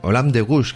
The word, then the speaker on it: Olympe de Gouges: Muy bien, pues nada, que vamos a hablar de Olympe de Gouges, que hemos dicho que se pronuncia así después de mucho estudio al respecto.